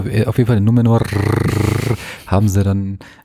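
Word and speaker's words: Númenor: Numenor. Numenor. – Das reicht schon. Du, du musst nicht rollen. Du musst nicht rollen, es reicht. Im Deutschen rollen sie auch nicht.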